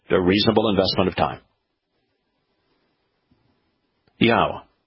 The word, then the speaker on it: Yahweh: It's Yahweh. But all you have to do is examine every Hebrew word with a yod, with a hey, and with a waw. And look how those letters are pronounced. It's Yahweh.